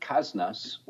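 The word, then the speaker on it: Kasnas: So from there, we headed west to a place called Kaznas, which is spelled just like Kansas, only the N and the S are transposed. So that's K-A-S-N-A-S.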